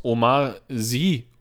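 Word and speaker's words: Omar Sy: Eigentlich und ähm, der wird gespielt von, ich, ich hoffe, ich spreche es richtig aus, Omar Sie oder ich glaube, Sie wird das ausgesprochen.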